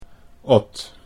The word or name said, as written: Ot